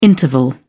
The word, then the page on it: interval: British English